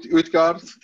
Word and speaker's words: Utgard: Have I said that right? Australians butcher everything. I'm so sorry. Did I say that right? Utgard? – Yeah, that's how we would say Utgard. The U is varying a little bit on where you're from, but you got it.